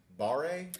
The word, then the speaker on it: barre: You mentioned it's spin and it's barre. – It's pronounced bar.